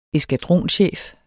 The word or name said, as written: eskadronchef